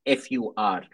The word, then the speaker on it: pho: You can't fur written PHO, but spelt FUR, pronounced FUR. Is